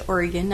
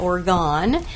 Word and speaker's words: Oregon: So, and it's pronounced Oregon, not Oregon, where most people mispronounce it.